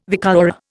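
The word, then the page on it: vicuña: Indian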